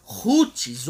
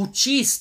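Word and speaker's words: Houthis: Esses são chamados de Rutis ou Utis, tanto faz a pronúncia, eles são zaiditas.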